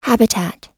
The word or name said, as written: habitat